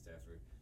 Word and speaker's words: Standefer: But thank you very much, Dr. Standefer.